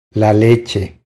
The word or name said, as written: La leche